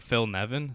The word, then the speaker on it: Nevin: I found it so fascinating that Phil Nevin, is that how you say it? The third base coach? – Yep.